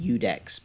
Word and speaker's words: Iudex: And on Twitter, I'm going to spell it out for you, IUDEX_Phil, which is pronounced UDEX, but nobody ever, if I said UDEX, you wouldn't spell it that way.